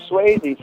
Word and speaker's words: Swayze: Yeah, Patrick Swayze. First of all, it rhymes with baby. And it rhymes with baby, it rhymes with crazy, it rhymes with daily, on the daily.